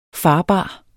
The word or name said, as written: farbar